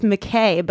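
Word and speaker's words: McCaig: It's McKay, but it's spelled like McCaw.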